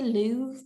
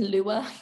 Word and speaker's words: Louvre: I think you say Louvre, but I was saying Lua. I think it's Louvre.